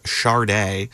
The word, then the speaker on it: Sade: The artist spelled Sade is pronounced Sade, Kiss of Life.